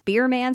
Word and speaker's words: Bierman: And that's Bierman spelled like beer man.